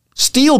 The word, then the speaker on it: Stihl: Steel products are some of the best in the business. Steel is a German company. The name is spelled STIHL.